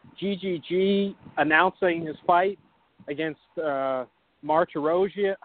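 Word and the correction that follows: Martirosyan: How do you say his name? I struggle with these Armenian names. – Vanis Martirosian. – Martirosian. Thank you.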